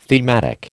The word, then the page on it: thematic: American English